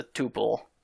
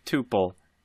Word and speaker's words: tuple: It's a space instead of a comma, and it worked just fine because it's already enclosed in parentheses as a tuple. – There are two main pronunciations of tuple. Tuple and tuple. Neither is incorrect.